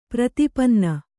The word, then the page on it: ಪ್ರತಿಪನ್ನ: prati panna